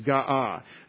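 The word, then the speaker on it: ga'ah (גאה): And the word is G-A-A-H, ga'ah. And that's gimel, aleph, hey. And we pronounce that when we write it as a verb in the perfect tense, we pronounce it ga'ah. And that's hard. And I'll tell you why it's hard. Because it's got two guttural words in it. And those words are just kind of air sounds, ga'ah.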